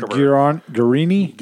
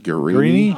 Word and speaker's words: Guarini: I can't even say what's his how you pronounce that. – Guarini. – Guarini.